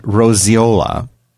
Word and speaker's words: Roseola: and roseola. – Roseola. – But basically. – What did I say? Rosacea? Rosicrucians. – Rosacea? This is spelled R-O-S-E-O-L-A.